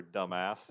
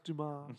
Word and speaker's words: Damas: Well, anyway, Bertilla Damas, or dumbass, did Beverly for the second time. – It's pronounced Dumas.